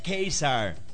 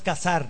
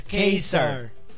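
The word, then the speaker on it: Ka-Zar: Y por cierto, como dice Stan Lee, se pronuncia Kaiser. Él mismo lo dice. – Sí, no es Cazar. – No es Cazar, es Kaiser.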